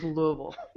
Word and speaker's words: Louisville: The city was named after King Louis, correct. We do not say that. It is Louisville. It's as if there were more syllables and letters involved. – Less movement of the tongue. Louisville. – Just let it roll over there. Just power through that word.